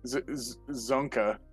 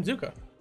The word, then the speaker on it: Zuko: Zonka. – Zuko. – Prince Zonka. Zuko. Danny Zuko. The guy from Greece. – That's legitimately, that's how you say his name, Zuko.